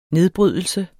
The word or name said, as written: nedbrydelse